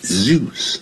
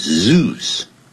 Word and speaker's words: Zeus: In British English they will say Zeus 啊，在英式的英语他们会讲 Zeus，在美式英语念成 Zeus。